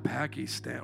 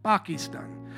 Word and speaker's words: Pakistan: You got to say it like how they say it. It's not Pakistan or Pakistan. It's Pakistan. Yeah, Pakistan. God, I'm not sure about this trip now.